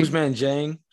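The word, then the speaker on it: Ousmane Dieng: Am I saying that right? – Usman Dieng. – Dieng? Yeah, okay, Dieng.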